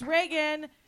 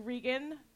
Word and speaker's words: Regan: Hey, it's Regan. It's spelled like Regan because my mom named me after the president, but she spelled it wrong because she's a Republican.